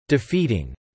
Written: defeating

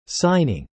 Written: signing

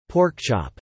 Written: pork chop

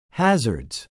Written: hazards

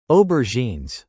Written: aubergines

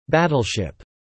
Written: battleship

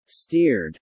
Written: steered

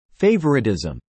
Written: favoritism

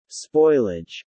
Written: spoilage

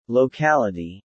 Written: locality

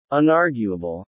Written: unarguable

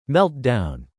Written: meltdown

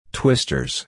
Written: twisters

